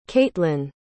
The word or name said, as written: caitlynn